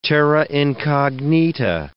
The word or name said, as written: terra incognita